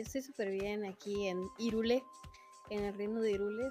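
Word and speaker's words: Hyrule: Bienvenidos a un nuevo episodio de Ikigai Podcast, el mejor show de todo el reino de Hirule, porque se pronuncia Hirule según el mismo eh, Nintendo.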